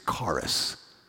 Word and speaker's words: charis: The word in Greek for grace is charis, C-H-A-R-I-S, we would spell it in English.